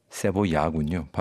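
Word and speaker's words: cebolla: 1930년대부터 50년대까지의 그 멕시코 시티 중심으로 한창이었던 볼레로, 카바레, 어구스틴 라라의 곡들 뭐 이런 것들을 이야기하면서도 자신의 나라 칠레의 무지카 세볼라 세보야군요. 발음이 세보야 같은 이런 신파 발라드 그리고 엔카 여기가 독특하죠.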